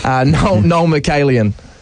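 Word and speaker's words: Noel Mikaelian: Can you say his name again? I can't remember how to say it. – That says it all. Noel Michalian.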